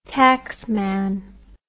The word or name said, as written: taxman